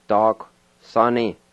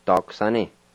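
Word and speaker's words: daquisani: And the U-I is silent. You just kind of sound out the Q sound. So here's how it sounds. Dark sunny. Dark sunny.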